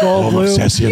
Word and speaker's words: Goldblum: Jeff Goldblum.